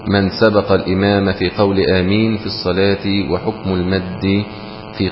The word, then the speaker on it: آمين: أما كلمة آمين فنطقها همز حرف همزتين بدل أمين، ألف على همزة وألف على همزة أمين، فسهلت فسميت آمين، فهو مد بدل مقداره حركتان فقط.